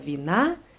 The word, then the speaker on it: вина: Bei Wina ist die Betonung auf A. У Стефана много вина, пива и водки.